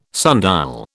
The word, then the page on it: sundial: British English